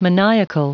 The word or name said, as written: maniacal